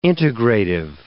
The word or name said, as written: integrative